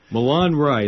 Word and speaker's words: Milan Rye: Milan Rye, like the city in Italy and the bread yeast. – That's how you say it.